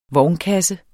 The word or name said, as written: vognkasse